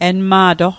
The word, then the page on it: enma'toq: Mi'gmaq